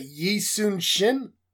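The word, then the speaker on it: Yi Sun-sin: I don't know if I'm going to pronounce that right, but is that Yi Sun Shin? – I believe so. – Yeah. So, Yi Su Shin, I got this card.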